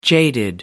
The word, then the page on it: jaded: American English